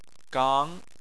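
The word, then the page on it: gang2: tone 2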